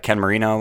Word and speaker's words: Ken Marino: A lot of Ken Marino was also in this.